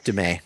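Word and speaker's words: Du Mez: I can't resist recommending, although listeners here are probably already familiar with the book, Jesus and John Wayne, Kristen Cobes Dumais. I might be mispronouncing her name. – Yeah, Dumais. Yeah.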